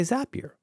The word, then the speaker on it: Zapier: Zapier. Rhymes with happier. I'm so glad you told me that.